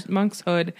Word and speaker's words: monkshood: And so Snape also asks Harry what the difference is between Monkswood, is that how you pronounce it? – Monkshood and Wolfsbane.